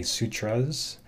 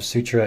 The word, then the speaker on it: sutra: And sutra means thread, and a common mispronunciation of this actually is to say sutras, but it's the plural of sutra is sutra. So the S is a Western English sort of thing that we tend to tack on there, but it's actually yoga sutra is singular and plural.